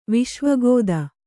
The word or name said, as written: ವಿಶ್ವಗೋದ